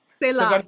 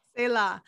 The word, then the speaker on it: Selah: Say la, yes. – You have the Jewish background, so I know I know how to say it now, because that's yes. – Selah.